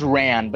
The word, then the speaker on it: Ran: There's a beginning of Mando arriving at a spaceport to meet with an old friend named Ron. It's pronounced Ran, but that's stupid for a job.